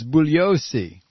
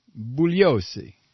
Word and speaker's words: Bugliosi: I believe the G is silent, as it is in lasagna. If you address him as bugliosi, I'll bet he'll appreciate you more.